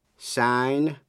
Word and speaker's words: sign: Sign, signature. Âm G trong chữ sign câm, nhưng phát âm trong chữ signature, danh từ. Trong chữ sign, S-I-G-N, nghĩa là ký tên, âm G câm, không phát âm.